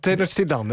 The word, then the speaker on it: Tenochtitlan: En dat was, dan moet ik het even goed uitspreken, Tenochtitlan. Zeg ik dat goed? – Tenochtitlan. Het bekt niet heel lekker, maar zo heette het.